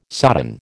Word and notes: sodden: American English

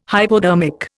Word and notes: hypodermic: Indian

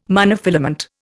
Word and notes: monofilament: Indian

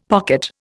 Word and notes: pocket: Indian

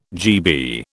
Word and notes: GB: British English

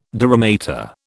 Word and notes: dura mater: British English

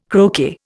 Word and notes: croquet: Indian

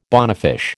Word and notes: bonefish: American English

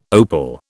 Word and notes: opal: British English

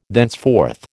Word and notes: thenceforth: American English

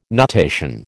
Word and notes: nutation: American English

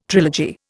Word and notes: trilogy: Indian

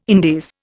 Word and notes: Indies: Indian

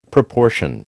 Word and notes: proportion: American English

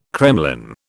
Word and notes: kremlin: British English

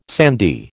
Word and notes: sandy: American English

